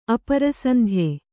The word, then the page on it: ಅಪರಸಂಧ್ಯೆ: kannada